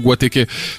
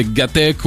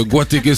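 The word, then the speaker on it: Guateke: Et sauf que si je le mettais tel quel, de la façon dont ça t'écrit, en France, je pense qu'on aurait prononcé gatec ou un truc comme ça.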